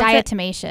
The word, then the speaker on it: diatomaceous: How do you pronounce diatomaceous. – It? Diatomaceous mm-hmm. earth, which you use outside.